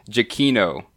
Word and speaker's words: Giacchino: Giacchino is how you pronounce it.